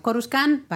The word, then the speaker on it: Coruscant: Se ve Coruscant, aunque bueno, aquí en inglés lo llaman Coruscant. ¿Cómo lo tengo que pronunciar Coruscant. yo esto? – Coruscant, vale, pues vemos una Coruscant súper gris.